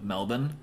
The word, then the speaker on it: Melbourne: Also, congratulations on your pronunciation of Melbourne. – Melbourne. – I think our Australian listeners will appreciate that. – I really hope so. I hope I did it justice. – Yeah, we didn't say Melbourne.